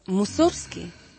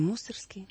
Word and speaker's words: Мусоргский: Но вот так же и композитор Мусоргский, если правильно. Но мы сейчас привыкли, мы говорим Мусоргский.